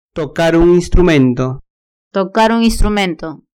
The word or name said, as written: tocar un instrumento